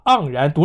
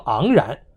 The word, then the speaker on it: 盎然: 盎司的盎念四声，习近平居然就能把盎然读成了昂然，读完之后居然还昂然自得地瞥了旁边的马克龙一眼。